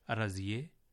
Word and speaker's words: رضیہ: آپ نے توجہ کی ہوگی کہ ان کا تلفظ فارسی میں رضیے, مرضیے اور طاہرے کیا گیا ہے, یعنی چھوٹی ہے کو جو قاعدہ ہم نے آپ کو بتایا ہے اس کے تحت بڑیے میں تبدیل کر دیا گیا ہے.